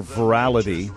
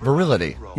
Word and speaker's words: virality: V-I-R-A-L-I-T-Y. Mm-hmm. How would you pronounce that? – Virility. – Yeah, but when I think of virility, I think of power, not going viral. – Interesting, yes. – But virality. I think it's... All right, how do we say it? Virality. – Yeah, I think I'd go with that.